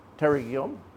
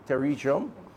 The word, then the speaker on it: pterygium: 1,192 cataract, 655 pterygium, yeah, let me pronounce that properly, pterygium, 655, and some 773 cases have been rescheduled during the period.